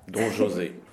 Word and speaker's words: Don José: With Carmen, we stick with her name pronounced basically in the Spanish way, basically pronounced with the way we expect it. But Don José, then we pronounce that the French way.